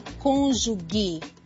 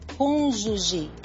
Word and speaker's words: cônjuge: Lembre-se, o G antes de E e I em português tem som de J. Portanto, nada de cônjuge. A pronúncia correta é cônjuge.